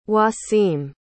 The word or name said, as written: waseem